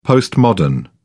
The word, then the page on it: postmodern: British English